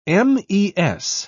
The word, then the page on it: mes: British English